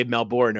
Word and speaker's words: Melbourne: Some people will say Melbourne over here, Melbourne. But it's Melbourne.